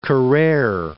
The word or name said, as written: Carrere